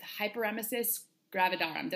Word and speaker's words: hyperemesis gravidarum: During her first three pregnancies, Amy was diagnosed with hyperemesis gravidarum. Did I say that right? – Yes, you did.